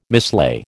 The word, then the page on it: mislay: American English